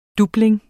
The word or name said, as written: DOBLING